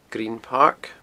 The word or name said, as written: Green Park